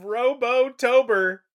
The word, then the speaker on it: Robotober: And we are in the midst of Robotober, which is where we're going to talk about a bunch of... – You're saying it wrong. It's Robotober. Robo. – Thank you. – Tober. – Robotober.